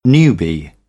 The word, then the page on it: newbie: British English; juː